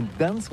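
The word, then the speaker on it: Gdańsk: Ça s'écrit G-D-A-N-S-K. On dit Gdansk ou Gdansk, on prononce le G ou pas?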